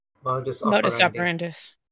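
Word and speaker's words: modus operandi: He went to bat and argued against immediately getting all of you imprisoned or even dealt with discreetly, as per regular program Mondo's Operatus. I know I'm butchering that. – Modus operandis.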